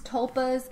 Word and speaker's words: Tulpa: And because of that, the cult needed a new vessel for the being, so they actually used James as a tupla. So, which means... Tulpa. – T-U-L-P-A. Tulpa. – I got it spelled Tulpa. Right, just not pronounced right.